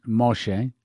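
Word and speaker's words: Moshe (משה): But Noah's mom, or excuse me, Moses' mom, by the way, Moses in Hebrew is pronounced Moshe, which means drawn out.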